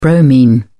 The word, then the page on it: bromine: British English